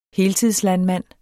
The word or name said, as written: heltidslandmand